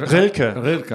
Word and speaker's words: Rilke: Rilke. R-I-L-K-E.